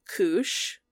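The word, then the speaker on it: Kusche: So Larry Koosh, rhymes with douche, hilarious, author of The Bermuda Triangle Mystery Solved, published in 1995, argued that many claims of writers are exaggerated, dubious, and unverifiable.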